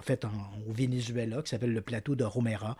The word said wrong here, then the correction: Roraima: Roraima. Voilà, j'ai toujours de la difficulté à le prononcer. – C'est correct. Roraima.